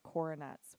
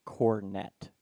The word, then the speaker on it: cornet: Surely everyone listening does not know about coronets, right? I cannot be the only one. – Okay. Sorry, I'm mispronouncing it. It's cornet, not coronet. It's just cornet. That's a cornet.